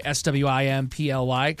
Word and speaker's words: Swimply: Swimply. Kind of like Simply, but Swimply.